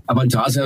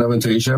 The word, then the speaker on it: Avantasia: wie spricht man es richtig aus? Ich habe es nie rausgefunden. – Avantasia in Deutschland und Avantasia im Ausland.